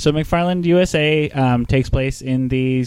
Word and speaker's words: McFarland: So, in other news, Disney released a movie recently called McFarlane USA. – McFarland, USA. – Oh, they renamed it? – Yeah. – I'm pronouncing that wrong? – Yes.